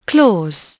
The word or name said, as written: claws